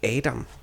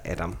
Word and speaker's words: Adam: Og øh, det navn, jeg har, er Adam, men med den internationale øh, engelske udtale. Ja. Altså Adam.